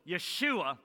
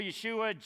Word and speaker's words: Yeshua: So Yeshua would be how we'd say that.